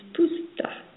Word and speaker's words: Spusta: You are here with musician and hypnotherapist Leigh Spusta. Am I saying that correctly? – You are saying it correctly. Spusta, like I'm supposed to be doing something.